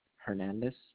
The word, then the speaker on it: Hernandez: Rafael, R-A-F-A-E-L. – And last name? – Hernandez. H-E-R-N-A-N-D-E-Z. – H-E-R-N-A-N-D-E-Z? – Yes.